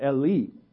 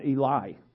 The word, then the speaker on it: Eli: Every Jew would have known what Eli means, and it's not it's pronounced like that, Eli, not Eli.